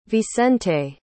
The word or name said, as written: vicente